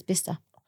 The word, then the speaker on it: Bicester: I thought it was Bicester. Like, was it the way I read it phonetically? – Yeah, like Manchester, yeah. – And they're like, where's Bicester? – Oh, no way. And what's called? – Bicester. – Apparently it's Bicester.